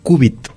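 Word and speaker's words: cúbit: La palabra es qubit y se escribe C-U-B-I-T, con acento qubit en la U, ajá.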